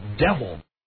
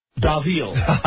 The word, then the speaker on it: devil: The word is devil. However, it was Davil.